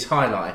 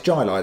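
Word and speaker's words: Jai Alai: Cigar City is High Lai. – Yeah. – So, and this is... The label's beautiful. – Okay, so it's Jai Lai then, isn't it? – Well, no, it's High Jai.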